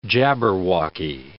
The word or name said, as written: jabberwocky